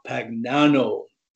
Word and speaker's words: Pagnano: We got a special, very special guest for you today, the great Rob Pagnano.